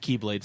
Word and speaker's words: Keyblade: Dude, the freaking, like, the key, like, the X blade that, like, Vanitas Ventus make. It's pronounced Keyblade, sir.